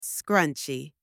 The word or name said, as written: scrunchie